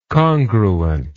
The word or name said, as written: congruently